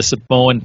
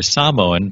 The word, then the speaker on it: Samoan: It's interesting hearing kind of the Samoan perspective, or Samoan perspective, if you want to say it right, after listening.